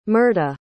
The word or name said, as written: Myrta